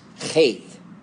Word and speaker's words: Heth: But in Hebrew, it's pronounced Heth. You got to get that guttural sound. It's kind of like a CH in the back of your throat sound.